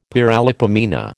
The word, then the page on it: paralipomena: American English